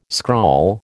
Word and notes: scrawl: American English

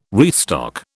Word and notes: restock: British English

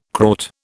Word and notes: Kraut: British English

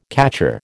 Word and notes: catcher: American English

